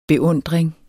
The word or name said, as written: beundring